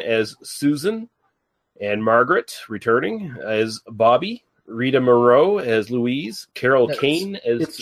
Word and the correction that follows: Moreno: I'm sorry, it's Rita Moreno. That's one name you do not get to pronounce in this house.